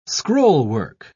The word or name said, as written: scrollwork